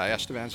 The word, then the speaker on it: Estevan: So, tell me about growing up in, is it Estevan, Saskatchewan? – Yeah, Estevan, Saskatchewan.